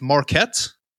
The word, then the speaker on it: Marquette: Because at the time of Jeffrey's birth, Lionel was a chemistry student at I believe this is pronounced Marquette. – Yeah.